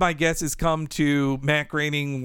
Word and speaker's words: Groening: he said an internal joke they had was uh, Groening rhymes with complaining.